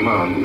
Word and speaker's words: Mahon: Malhoon. – You mean Gavin Mahon? – Yeah, Malhoon, I thought.